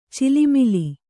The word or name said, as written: ಚೆಲ್ಲುಮಲ್ಲಿ